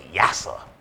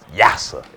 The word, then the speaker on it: Yassa: Y-A-S-S-A. Yasa. Pronounced Yasa.